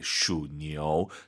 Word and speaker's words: 属牛: 예예, 슈니오. 삼성 이성은 아무리 반복을 해드려도 지나치지 않다고 생각합니다. 슈니오, 아이유 사이에 오 넣어서 니우가 아니라 니오 기억하시기 바랍니다.